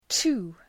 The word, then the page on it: two: tu: